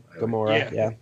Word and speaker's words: Gamora: I mean, I really enjoyed Chris Pratt a lot, and the stuff with, how do you pronounce, is it Gamora? Is that how we pronounce her name? – Gamora, yep.